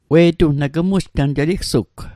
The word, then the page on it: we'tung: Mi'gmaq